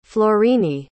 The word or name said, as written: florine